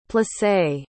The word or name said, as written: plissé